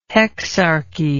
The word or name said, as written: hexarchy